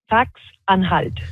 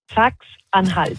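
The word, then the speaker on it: Sachsen-Anhalt: Saxe-Anhalt. – Alors, plus sérieusement, ce qui retient vraiment l'attention, c'est cette victoire de l'extrême droite en Saxe-Anhalt.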